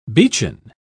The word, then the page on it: beechen: British English